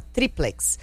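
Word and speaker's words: tríplex: Ó, rapidinho, só pegar aqui, eu achei a resposta do nosso ouvinte de Curitiba, o Isaac, falando, a palavra é uma paroxítona, a sílaba tônica é a penúltima, triplex.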